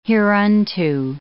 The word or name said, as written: hereunto